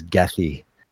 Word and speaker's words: Goethe: Okay, well, I would just the proper pronunciation of Goethe in Chicago is Gethy. So there you go. – Gethy.